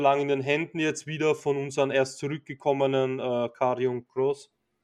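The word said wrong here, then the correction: Karrion Kross: Habe ich das richtig ausgesprochen? – Um, ähm, Carrion Cross.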